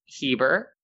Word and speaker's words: Heber: Is it Herber? Yay. Is it Heber Springs? – It's pronounced Heber. – Oh, Heber. I thought you were saying Hebrew. – Oh, no. Heber.